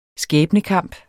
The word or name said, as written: skæbnekamp